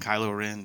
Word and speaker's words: Kylo Ren: The new Star Wars trailer has the new villain I believe his name is Kylo Ren. I don't know how to pronounce it. It's K-Y-L-O-R-E-N. So I was thinking Kylo Ren, something like that.